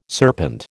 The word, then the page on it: serpent: American English